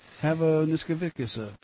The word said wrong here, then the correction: Hazanavicius: It's Hazanavicius.